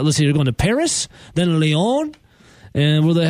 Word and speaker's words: Paris: You don't pronounce the S if you're going for the French version. It's Paris.